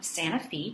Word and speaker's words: Santa Fe: Her grandmother, Gemma, a petite red-headed woman, tried to integrate her Methodist church in 1901, in the small town of Santa Fe, Tennessee. It's spelled like Santa Fe, but it rhymes with Tennessee.